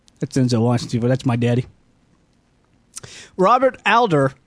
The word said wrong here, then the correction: Adler: did I say his name right? Father of the remote control. – Are you sure it's not Adler? – That's what I meant to say. Adler.